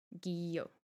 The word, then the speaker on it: Guyot: And that's spelled G-U-Y-O-T, so like Gaillot. – Gaillot. What was the guillo? – Guillo. – Guillo.